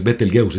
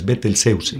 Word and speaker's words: Betelgeuse: Entonces, por ejemplo, Betelgeuse, que se dice así, ya aprendí también cómo se pronuncia Betelgeuse, no es Betelgeuse, es Betelgeuse.